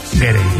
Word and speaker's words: Gere: pensando yo que si te apellidas Ger, bueno, ¿cómo se dice Ger o Gerre? – Gerre. Me ha hecho gracia porque has dicho Richard Gere y luego el hijo era Gere.